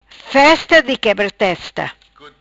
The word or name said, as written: Festa di Quebra Testa